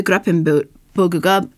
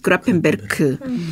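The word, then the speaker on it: Gräfenberg: 있고 그 이름은 어, 이것에 대해서 처음으로 기술한 어네스트 그라펜버그가 이제 미국식 발음이고 에르네스트 그라펜베르크 이거 이제 그라펜 그라펜버그 반점이라고 이제 명명한다.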